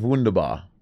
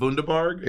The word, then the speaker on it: Wunderbar: It's pronounced Wunderbar. – It's not Wunderbarg? – It's not Wunderbarg. No.